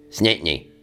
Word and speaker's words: St. Anthony: And then if you're from St. Anthony becomes one syllable. It's just snitney. Snitney.